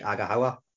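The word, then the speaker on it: Aghahowa: You'd find out about Julius Agawawa. I didn't pronounce that right, Agahawa.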